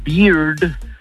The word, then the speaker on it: beard: Porque con, o sea, la barba se pronuncia beard, el oso se pronuncia bear, ¿eh? Y la cerveza se pronuncia beer. Pero con R al final.